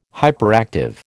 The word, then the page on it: hyperactive: American English